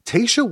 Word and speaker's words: Tayshia: If I remember correctly, Tayshia wasn't, and I'm always worried about how I pronounce her name.